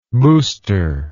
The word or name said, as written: boosterish